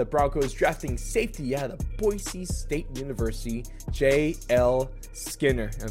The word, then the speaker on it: Boise: It is Boise State. There's my PSA for the day. Little soft S's all around.